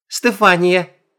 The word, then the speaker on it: Стефания: «Ты кем будешь?» «Стефания. Ударение на А.